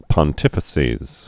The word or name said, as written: pontifices